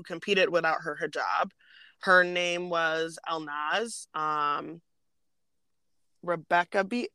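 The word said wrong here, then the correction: Rekabi: I don't know how to pronounce that. But hello, girl. – Rakabi. Elnaz Rakabi. – Rakabi. There we go.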